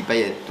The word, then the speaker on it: Payet: Non, c'est à La Réunion d'où je viens, ça se dit Payette. Donc effectivement, ceux qui disent Payette, ils ont raison.